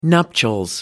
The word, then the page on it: nuptials: American English